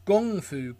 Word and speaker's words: kung fu: and it's a low budget kung fu, or I should say gung fu comedy, because that's how you pronounce kung fu if you're Chinese.